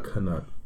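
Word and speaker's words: Knut: And so, like, a penny for your thought, a nut for your thought. – Yeah. – It's a little weird, but I like it. Oh, sorry, sorry. – Say it correctly. – You're right. It's a canut.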